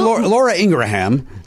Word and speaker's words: Ingraham: Did you see Laura Ingraham, and I learned within the last 2 years that's how you say her name, said that the country is too depressed and serious.